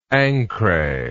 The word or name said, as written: ancre